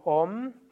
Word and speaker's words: Om: O ist auch im Sanskrit nie kurz ausgesprochen, sag also nicht Om oder Haribol, oder Hari Om oder Bolo, sondern das O ist immer lang. Also es ist Om und Haribol und Bolo. Das O ist lang.